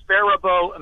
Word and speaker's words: Faribault: I believe it's pronounced Faribault, Minnesota.